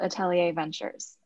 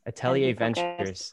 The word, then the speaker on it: Atelier Ventures: Yes, I'm the founder of a VC firm called Atelier Ventures. – Atelier Ventures.